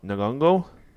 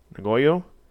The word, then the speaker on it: Nyong'o: The actress's name is Lupita Nyong'o. Nyong'o.